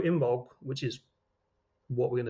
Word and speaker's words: Imbolc: But yeah, so Imbolc. I-M-B-O-L-C is how it's spelled, but apparently you say it with a G, but I'm going to say Imbolc.